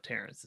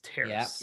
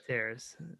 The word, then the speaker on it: Terrace: It's not Terrace, it's Terrace. Yeah, you got it. – Lakeview Terrace. – Terrace, yes.